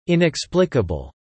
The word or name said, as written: inexplicable